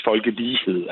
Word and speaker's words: folkelighed: Som, som fandt på det, og som sagde, at, at det skulle udtales folkelighed. Altså, der skulle være lighed i folket, eller noget burde ligne folket.